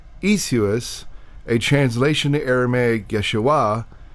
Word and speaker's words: Yeshua: That's how they said Yeshua.